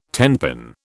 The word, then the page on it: tenpin: British English